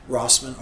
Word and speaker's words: Rossman: Anybody that wants to Google or search my name, Gregg is spelled with two G's, G R E G G, Rossman, R O S S M A N.